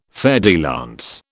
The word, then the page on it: fer de lance: British English